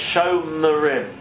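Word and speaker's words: Shomrim (שומרים): And Shomrim, which is a cholavor, which is the long pronunciation, so it's Shomrim.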